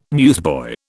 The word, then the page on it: newsboy: British English